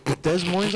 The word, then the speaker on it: Des Moines: Des Moines, Iowa. Am I saying it right? – Yeah, you're saying it fine.